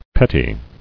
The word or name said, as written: Pettily